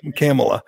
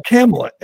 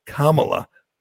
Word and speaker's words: Kamala: Camel, Camel, Cam, Camilla.